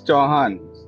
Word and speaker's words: Chauhan: I don't know if I pronounced your name correctly. – Yeah, it's Chauhan.